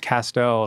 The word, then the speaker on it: Castel: It's Castel, rhymes with pastel.